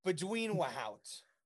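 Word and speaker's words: Bezuidenhout: Is that how you say it? – Bezidenhout. – Bezidenhout. – Bushel of wheat. – I'll get it right eventually. Look, I'm trying, okay? At least I'm trying. How do you say it? – Working on it. Bezidenhout.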